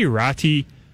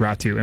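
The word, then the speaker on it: Räty: Some of the pronunciation lists in the NHL mm-hmm. have it as Ratti. – Yeah. – But I believe it's supposed to be pronounced Ratu. And we learned that when he was playing in the World Juniors. And Gordon Miller called him Ratti, I believe, to start the tournament, and then was corrected, and he spent the rest of the tournament calling him Ratu.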